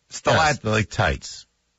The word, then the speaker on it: stalactites: Stalactites. – There's no G in stalactites.